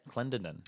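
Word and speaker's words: Clendenin: Yeah, that's pronounced right, I'd say. Clendenden. – I feel like I just stuttered a little bit. – Clendenden, yeah.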